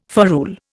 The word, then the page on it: ferrule: Indian